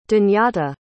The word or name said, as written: dhanyata